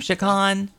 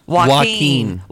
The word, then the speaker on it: Joaquin: Jacqueline Phoenix is, how do you say his name? Shaquan? – Joaquin.